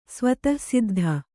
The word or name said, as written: ಸ್ವತಃಸಿದ್ಧ